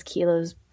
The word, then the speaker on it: Keilah: So I'm on Instagram, Facebook, and then I'm gonna spell it because Keila's kind of difficult. It's yes. – K-E-I-L-A-H and then last name J-U-D-E.